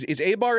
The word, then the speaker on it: Eibar: Is Eibar any good? Are they a bottom feeder? – I was about to tell you. Eibar, in Spanish, Eibar, as you pronounce it, is not that great.